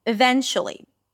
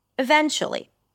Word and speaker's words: eventually: The then is the primary stress, so you also go a little higher in pitch. Eventually. Eventually.